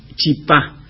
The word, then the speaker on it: chipá: Mira, dice chipa y acá del otro lado dicen chipá. – Claro, el corriente de Chaco es en chipá, claro. Y está bien dicho chipá, porque las voces guaraníes se acentúan en la última sílaba, son agudas.